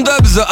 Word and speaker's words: DVBBS: Alors ça s'écrit DVBBS, mais ça se prononce Dubs.